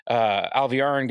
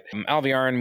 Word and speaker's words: Alviarin: You don't learn anything new, because even after this, Alviarin goes to meet with Misana, and it's the way her name is spelled, there's no pause there, but like when Kate Redding says it, it sounds like there's like a apostrophe in it or something.